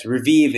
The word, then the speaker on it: Revieve: So it's pronounced Revieve, is that right? – Yeah, that is correct. Yes. Revieve is the way we pronounce the name of the company.